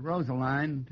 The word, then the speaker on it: Rosaline: They pronounced it Rosaline to rhyme with brine.